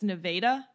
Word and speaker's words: Nevada: The first A in Nevada, pronounced A. Oh, I guess it's Nevada. Shit. I've been pronouncing it wrong the whole time.